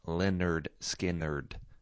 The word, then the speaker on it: Lynyrd Skynyrd: That is Things Going On from 1973's pronounced Lynyrd Skynyrd, which is a fun debut album name to make sure that people know how to say the name of your band.